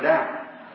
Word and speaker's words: Daugh: He's a German. D-A-U-G-H. And I pronounce it Dow.